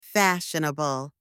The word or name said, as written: fashionable